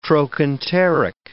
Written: trochanteric

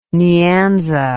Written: nyanza

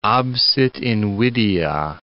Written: absit invidia

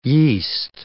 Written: yeast

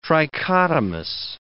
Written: trichotomous

